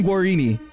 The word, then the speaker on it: Guarini: Justin Guarini.